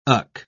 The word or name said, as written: och